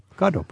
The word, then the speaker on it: Godot: Wait, how do you say it in Irish? Godot? – Godot. – Godot, but that's the correct way.